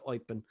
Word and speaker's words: Eupen: They're German speaking. The club songs, they refer to Eupen. So we'll go with the German pronunciation instead of the Flemish or the French one.